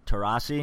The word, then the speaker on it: Taurasi: And then lastly, you guys are going to have to help me with the pronunciation of her name, but Diana Taurasi. – That's right. Nailed it.